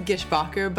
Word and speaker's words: Gishbaugher: We pronounce it Gishbaugher, but Gishbaugher. It was a made-up name, so nobody really knows.